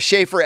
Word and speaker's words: Schaffer: Don't spell it like the Starbucks baristas will spell it, like N-I-E-L or K-N-E-E-L. Last name is Schaefer, S-C-H-A-F-F-E-R.